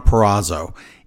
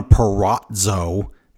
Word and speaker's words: Purrazzo: It's Deonna Purrazzo. It is not, as Excalibur says, Diana Perazzo. There's no T in there, so it's not that. It's not Palazzo.